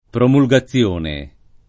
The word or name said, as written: promulgazione